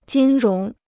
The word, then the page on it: 金融: jīn róng